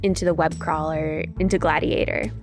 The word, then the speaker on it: GLADIATOR: Gladiator, is that how you pronounce it? – Yes.